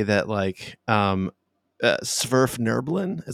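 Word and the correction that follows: Svirfneblin: is that how you say it, John? Every time you grimace, every time I say the damn word, say it for me. – Sverf Neblin. – Sverf Neblin? – Yeah.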